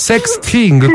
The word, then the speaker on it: sexting: Ho detto bene sexting, perché ridi, scusa? – Il modo? – Sexting, come si dice, scusami? – Sexting. – Ecco. – Esatto.